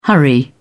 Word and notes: hurry: British English

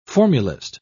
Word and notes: formulist: British English

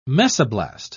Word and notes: mesoblast: British English

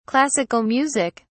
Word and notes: classical music: British English

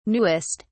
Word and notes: newest: British English

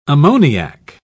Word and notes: ammoniac: British English

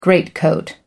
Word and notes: greatcoat: American English

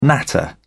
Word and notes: natter: British English

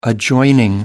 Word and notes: adjoining: American English

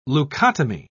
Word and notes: leukotomy: British English

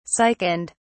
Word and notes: sickened: British English

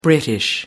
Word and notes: british: American English

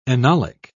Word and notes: enolic: British English

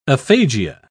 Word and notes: aphagia: British English

